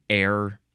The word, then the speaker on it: ere: The first one is ERE, and you pronounce it air.